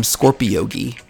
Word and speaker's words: Scorpiyogi: And if you'd like to walk with me, I'm Scorpiyogi, S-C-O-R-P-I-Y-O-G-I.